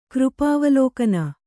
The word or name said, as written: ಕೃಪಾವಲೋಕನ